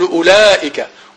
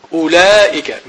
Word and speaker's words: أولئك: فلو هذه ترسم تكتب ولا تنطق، يعني لا ننطق بها، نقول أولئك أولئك من الهم من الهمزة إلى اللام مباشرة، أولئك أولاء أولاء، لكنها في الرسم تكتب الواو.